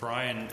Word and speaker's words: Brion: Brian is pronounced just like your name, but it's spelled B-R-I-O-N, which is unusual. Yes. – I didn't hear the first thing your mom said. – Teresa, T-E-R-E-S-A.